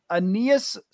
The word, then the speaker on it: Aeneas: Aeneas Smith from Texas A&M.